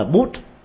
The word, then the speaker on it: Buddha: Nhưng mà đọc đúng âm của người Ấn Độ là Bút, Amitabh Bút, Nam Mô Amitabh Bút, thì vậy là chuẩn nhất.